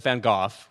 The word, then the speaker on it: van Gogh: The only, like, Britishisms that I'm super connected to are you pronounce Vincent van Gogh, Vincent van Gogh, which just sounds way better.